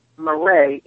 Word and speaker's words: Marree: It's Marree, M-A-R-R-E-E, with that accent on the last E, so it's like Renee.